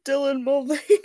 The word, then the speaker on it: Dylan Mulvaney: I thought that was Dylan Mulvaney.